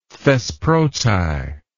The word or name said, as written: thesproti